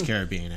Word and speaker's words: Caribbean: Oh, you're going to pronounce that wrong. – Pirates of the Caribbean. It is Caribbean, actually.